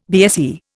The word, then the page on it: BSE: Indian